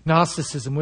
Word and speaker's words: Gnosticism: And one of those lies at the time of John being the popular ideas known as Gnosticism, spelled with a G, Gnosticism,